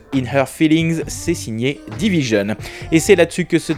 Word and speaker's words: DVSN: Enfin, direction euh, le Canada à la rencontre du duo Division, ça s'écrit DVSN, et c'est euh, Isaac qui nous parle de cet album RB, Amuse in Her Feelings.